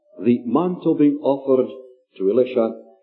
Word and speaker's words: Elisha: And being a Scotsman, I pronounce his name Elisha. It helps us to distinguish at least between Elisha and Elijah.